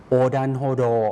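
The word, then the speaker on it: โ อดันโฮ โด (横断歩道): โอดันโฮโด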